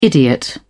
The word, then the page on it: idiot: British English